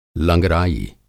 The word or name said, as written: लंगराई